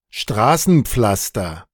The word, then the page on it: Straßenpflaster: [ˈʃtʁaːsn̩ˌp͡flastɐ]; Germany (Berlin)